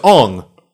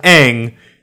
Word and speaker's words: Aang: Then you're saying like basically that's the same argument for people who are like, oh, Aang's name is Ong. In the fucking Last Airbender bullshit. – And it's like, no, it's not. We watched a show where his name is pronounced Aang the entire time.